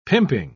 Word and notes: pimping: British English

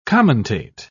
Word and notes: commentate: British English

